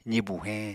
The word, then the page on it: zibu heñ: apatani